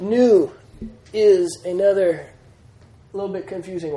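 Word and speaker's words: New (ν): And for your first couple weeks of reading Greek words, every time you come to a new, you're going to pronounce it like a V, and you just got to stop and remind yourself, nope, that's a N. N. New, as in new.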